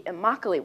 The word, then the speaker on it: Immokalee: This is a modern-day David and Goliath story in which a small organization of farm workers in South Florida called the Immokalee Workers, rhymes with broccoli, gained international significance by forcing the fast food industry to respect the labor rights of tomato pickers.